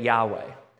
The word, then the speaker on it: Yahweh: But the J sound was never in Hebrew. So it was everything was always the soft J, the yah sound. So that is where we get Yahweh.